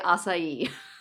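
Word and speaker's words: açaí: I'm going to say acai.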